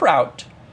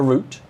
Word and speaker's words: route: Now, what you're going to notice down below here is this little thing called app route or root. Route? – Yep. – Root. I'll probably bounce back and forth between the two pronunciations.